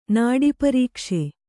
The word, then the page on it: ನಾಡೀಪರೀಕ್ಷೆ: nāḍi' parīkṣe